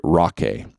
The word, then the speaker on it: Rockay: He says, look at Rocky. And I'm going to say Rocky because it's Rock, R-O-C-K-A-Y.